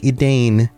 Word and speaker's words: Edain: Even though Edain is spelled E-D-A-I-N, it looks like Edain. Edain is the more accurate pronunciation.